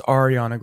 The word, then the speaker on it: Ariana: All right, so, Bunny, before you go, it's Ariana Grande, not Ariana. I know her fans absolutely hate that. Yeah. When people say it wrong.